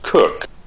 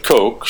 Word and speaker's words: Coke: We'd said the Brits over on the other side of the pond, they pronounce it Cook, not Coke. And in America, as we have said, we think things go better with Coke, so I guess we say Coke instead of Cook, but it's spelled C-O-K-E.